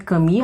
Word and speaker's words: Camus: Mas antes de falar um pouco desse romance, convém passar rapidamente pela pela autoria, pela figura obra de Albert Camus, que é o autor, é um autor franco, era um autor franco-argelino. É, o Albert Camus, apesar do nome dele se escrever Camus, se pronuncia Camus, justamente por essa origem francesa.